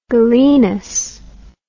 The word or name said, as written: Galenus